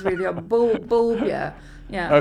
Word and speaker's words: Bulbjerg: How do you pronounce it? – It's Bulbja.